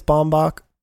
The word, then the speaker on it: Baumbach: I think it's Baumbach.